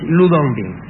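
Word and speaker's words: Lü Dongbin: Se escribe Lu Tongping y es Lu Dongbing.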